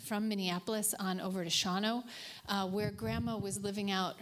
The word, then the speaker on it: Shawano: I am pronouncing it correctly, just so you know. Shawano, that's how you pronounce it.